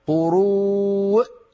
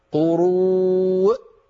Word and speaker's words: قروء: لا تقول يا هدى، لا تقولي قروء، لا تنطقي الهمزة مرتين، قروء فقط، أقفل الوترين الصوتيين. – ثلاثة قروء. – لا، أنت تنطقينها الهمزة مرتين، فقط أقفل الوترين الصوتيين مرة واحدة تخرج الهمزة، هكذا قروء وفقط.